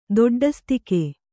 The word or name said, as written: ದೊಡ್ಡಸ್ತಿಕೆ